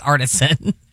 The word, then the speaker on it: artisan: and I read it as artesian. – Oh, okay. – Somebody called her like, I think it's artisan. I kept saying the artesian blah, blah, blah. – You were mispronouncing.